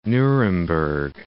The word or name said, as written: Nuremberg